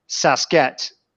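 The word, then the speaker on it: Sásq'ets: And the actual pronunciation in the Stehelis language is Sasket. And J.W. Burns changed that word a bit and called it Sasquatch. So that's why it's such a famous word today.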